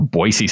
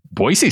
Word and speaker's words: Boise: Boise State. – You said it wrong the first time, and that makes people from Boise mad. – Did I say Boise? – Yeah, you can't go Boise.